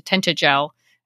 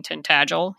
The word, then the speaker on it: Tintagel: Although my friend kept telling everybody we were going to Tintagel until someone finally said, "You mean Tintagel?" He didn't even know how to say it, but he was obsessed with it.